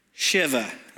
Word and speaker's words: Shiva: It's very important how you pronounce those two words together. That H is crucial. Sitting Shiva.